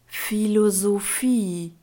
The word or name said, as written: philosophie